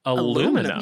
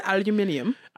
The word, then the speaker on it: aluminium: You didn't know that they said aluminium. – I think I knew, but I don't think I ever like actually, they say it a lot. – That's how they say it.